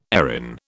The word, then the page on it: Erin: British English